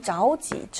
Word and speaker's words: 着急: 이 좌우지, 저가 아니라 좌우라는 것도 우선 중요하죠. 좌우지라고 읽는다는 거.